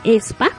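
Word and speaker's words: aespa: El nuevo grupo de la SM Entertainment, ya miren, según mi investigación se dice e SPA. Este... Eh, se llama ESPA. Se escribe a ESPA.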